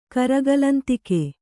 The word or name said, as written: ಕರಗಲಂತಿಕೆ